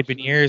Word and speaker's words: Beniers: Matty Beniers, is that how you say his name? – Yeah.